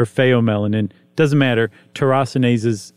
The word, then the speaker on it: tyrosinase: How would you pronounce it? – Just exactly like that, tyrosinase. – Oh, okay. That was tyrosinase. But that's how it's spelled, tyrosinase. But tyrosinase surely is the way that you'd say it, right? – I think probably so.